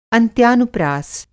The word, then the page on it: अंत्यानुप्रास: Hindi